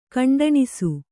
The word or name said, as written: ಕಂಟಣಿಸು